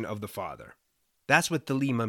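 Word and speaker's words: thelema (θέλημα): The word for will used here in Greek is pronounced thelema,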